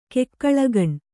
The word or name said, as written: ಕೆಕ್ಕಳಗಣ್